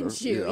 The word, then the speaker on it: Minshew: Minshew, how do you say it? Gardner. – Minshew, yeah.